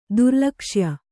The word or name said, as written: ದುರ್ಲಕ್ಷ್ಯ